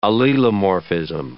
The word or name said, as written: allelomorphism